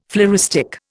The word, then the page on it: floristic: Indian